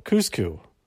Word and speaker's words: Kuzco: How do you pronounce his name? I forget. It's been a while since I've actually watched the movie. I think it's Cusco. Kuzco is his name.